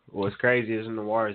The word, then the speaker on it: Noir: It's like Noir. Noir, I think that's how you pronounce Noir. – It because it's French.